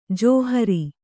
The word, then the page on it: ಜಾಹಿರು: jāhiru